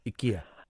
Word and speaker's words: IKEA: My friend's nan used to call it IKEA and we'd laugh. But then it turns out that's how you pronounce it. IKEA.